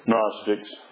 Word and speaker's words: Gnostics: I better pronounce it right. Gnostics. It's spelled G-N, so I always have to.